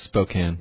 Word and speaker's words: Spokane: It's not like I'm saying that people should go out there and oh, am I pronouncing it wrong? – Yes, it's Spokane.